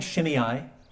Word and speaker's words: Shimei: I'm saying Shimei. Okay, you may hear another pronunciation, but Shimei.